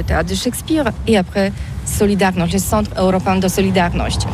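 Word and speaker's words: Solidarność: Alors solidarité, en français on dit Solidarnosc, mais ici à Gdansk on ne prononce pas Solidarnosc. – Solidarnosc.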